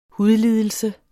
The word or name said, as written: hudlidelse